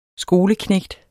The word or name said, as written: skoleknægt